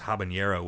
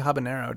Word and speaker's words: habanero: JXQZ says, just saw a Golden Corral ad where Jeff Foxworthy pronounced habanero with a tilde over the N. When I first saw the joke, I thought, surely no one says it like that. I do. – Is it? I guess people say habanero, don't they? – I get yeah, and I guess it's spelled with an H, and always has been.